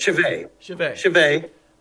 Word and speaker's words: Schvey: Schvey.